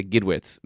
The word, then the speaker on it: Gidwitz: Well, I want to say Gidwitz. That was good. That was right.